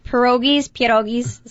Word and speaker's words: pierogies: Pierogies is how you say it.